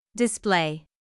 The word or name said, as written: display